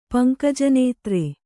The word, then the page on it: ಪಂಕಜನೇತ್ರೆ: kannada